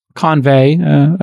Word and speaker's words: Convai: Well, my guest today is the co-founder and CEO of Convay, and it's spelled C-O-N-V-A-I if you're looking for it, but it's Convay, as in convey something.